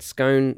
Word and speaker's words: scone: Obviously, I just said scone because it rhymes with bone.